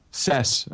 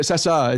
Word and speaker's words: Cessa: Cess. Okay. – He couldn't even he couldn't go that extra syllable to pronounce the a. – It's a lot. You get it. Cessa.